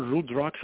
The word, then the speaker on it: Rudraksha: It, it's called Rudraksha. It's spelled R-U-D-R-A-K-S-H-A, Rudraksha.